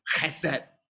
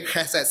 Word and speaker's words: chesed: chesed. Chesed, right? Okay, chesed, C-H-E-S-E-D, or H-E-S-E-D, but the H is pronounced with a kh sound, right?